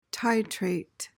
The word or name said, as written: titrate